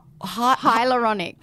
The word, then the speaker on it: hyaluronic: I never know how to say it. – It's a tricky one. There's lots of A's and U's and vowels in there. So hyaluronic. – Hyaluronic.